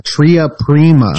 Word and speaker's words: tria prima: Paracelsus prima trima? – Yeah, tria prima. – Tria prima, okay. – Maybe I'm saying it wrong. Maybe it's prima trima. – No, no, you're right. – But it's something. It's something no, I'm learning. – You got it right.